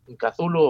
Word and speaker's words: Cthulhu: De Cthulhu o Chulu o Tulu, bueno, depèn, es pot dir de moltes maneres perquè mai va dir com es pronunciava.